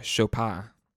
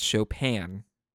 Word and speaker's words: Chopin: You know, David was playing Chopin. – Are you sure about that? – Or for those that prefer to pronounce it the English way, that's Chopin. But I prefer to say it the French way.